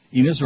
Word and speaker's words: Enos: Enos rhymes with Venus and Menus and Penis.